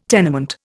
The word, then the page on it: tenement: Indian